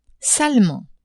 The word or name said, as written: salement